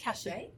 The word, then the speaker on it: Hachette: And it was published by Hachette, is it? Or Hatchet? How do you say it? – Hachette.